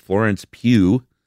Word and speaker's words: Florence Pugh: Alexandria Runyon says Florence Pugh. Is that how you pronounce her name? – That is.